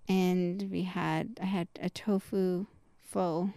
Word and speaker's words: pho: pho.